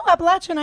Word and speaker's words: Appalachian: Appalachian. No, Appalachian, I hear is the correct really? – Pronunciation. Yes.